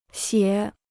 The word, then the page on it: 写: xiě